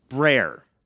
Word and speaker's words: Brer: Brer. Bear with an R after the B. Brer.